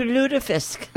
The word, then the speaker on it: lutefisk: Due to lutefisk. – That's how you say it.